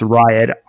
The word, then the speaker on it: Riatt: The name of the baby is, yes, I think it's pronounced Riot, R-I-A-T-T.